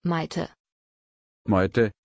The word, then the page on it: Maité: German